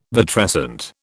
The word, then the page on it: vitrescent: British English